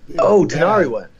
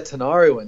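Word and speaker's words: Tinariwen: I got to see Tina Rowan. That's from Mali. – Tanariwen. – Is that how you say it? – Yeah, Tanariwen. Yeah, that's like heavy.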